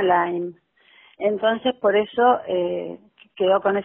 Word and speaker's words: Lyme: Sí, primero que me gustaría que pudieras deletrear el nombre de la enfermedad, es LYME. – Sí, la enfermedad se llama Lyme, que se escribe L-Y-M-E.